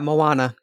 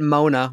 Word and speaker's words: Moana: Yeah, Moana. Yeah. – Okay. Yeah. Sorry. – I called it Mona when I first talked about it on the podcast, and I had to be corrected.